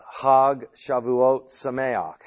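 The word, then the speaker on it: Chag Shavuot Sameach: And tomorrow they will say, there's a Hag Shavuot Sameach, Happy Pentecost. That's the Hebrew pronunciation of it.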